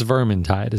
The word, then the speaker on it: Vermintide: it's Vermintide is how that's pronounced.